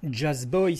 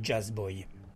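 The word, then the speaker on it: Jazzboy: Jazz Boy, ça s'écrit Jazz Boy, JAZZBOYS, mais ça se prononce à l'anglaise, paraît-il.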